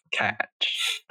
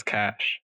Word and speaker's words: cache: Cache is no. Catch. I don't know. I've always pronounced it as cash. – It's because that's how it's pronounced.